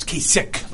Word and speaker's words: Kasich: It's Kasich, like a K, even though it's a CH. Very European, very German.